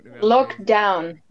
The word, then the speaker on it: Lockdown: Is het Lockdown of Lockdown uitgesproken eigenlijk? – Lockdown.